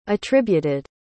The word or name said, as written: attributed